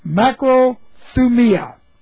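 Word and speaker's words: makrothumia: That's the word macro, M-A-K-R-O, thu, T-H-U, mia, M-I-A. Macrothumia. Now, I'm going to take a chance here. Where have we seen macrothumia in our studies?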